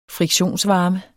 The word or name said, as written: friktionsvarme